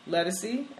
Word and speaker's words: Ledisi: a lot of other places have discussed Ledisi.